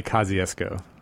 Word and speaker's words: Kosciuszko: In Mississippi, we say Kosciuszko. – Kosciuszko. – Not sure how they say it here, which I'm sure they would retain the Polish pronunciation.